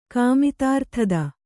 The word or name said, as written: ಕಾಮಿತಾರ್ಥದ